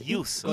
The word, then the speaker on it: Yuso: Menos mi apellido. – No, la puta, ¿te das cuenta? – Es ¿cómo es? – Yuso. Con Yuso. Latina.